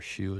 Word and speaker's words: SHU: If you go deep into the prison, the most secure place is a place known as a security housing unit, SHU, or SHU as it's pronounced.